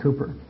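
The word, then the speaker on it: Cowper: The life story of William Cooper, now we've seen that name in the pastor's writing, spelled C-O-W-B-E-R, but pronounced by the English Cooper.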